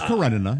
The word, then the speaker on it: Karenina: Oh, no, you said it right. It's Karenina. You're right. – Thank you. – Karenina.